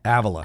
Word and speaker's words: Avila: Am I saying that right? Avila or Avila? – Avila.